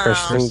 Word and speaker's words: Kristen: Mel, from my perspective, you pronounce Kristen Bell wrong. – So. Is it Kirsten? – Oh. – Kristen Bell.